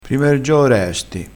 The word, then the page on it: prima e r gioo: dialettale